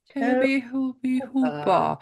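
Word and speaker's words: Tobe Hooper: Toby Hooper.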